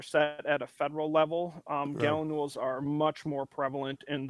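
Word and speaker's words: gallinule: Michael had emailed us the common gallinules.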